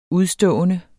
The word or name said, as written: udstående